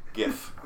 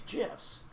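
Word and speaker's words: GIF: G-I-F-F. – Giff. – It's Giff. – Well, I don't know. There's some people who pronounce Giffs as Jifs. – Not with a G. Whoever told they're lame. Don't listen to them. I agree. – They're wrong.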